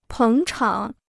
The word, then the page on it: 捧场: pěng chǎng